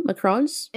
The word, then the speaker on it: Macron: Macron's? Yeah, Macron's, is that how wife. You pronounce his last name? Macron, yeah, Macron.